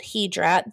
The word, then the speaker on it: Hydra: It's spelled Hydra in American. It's called Hydra.